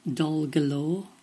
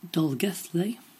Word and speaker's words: Dolgellau: It is spelt D-O-L-G-E-L-L-A-U. D-O-L-G-E-L-L-A-U. So did you say this one as Dolgalore? Dolgellau. Then the result is... Did you say... Dolgethley? Then it's right. One that was tweeted about earlier is spelt W-O-R-C-E-S-T-E-R.